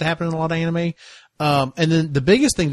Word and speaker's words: anime: It's pronounced anime.